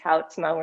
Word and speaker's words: Houtsma: His name is Jason Houtsma. I think I'm saying that correctly. – It's Houtsma, actually. – Yep. Oh, okay. There we go. It's Houtsma.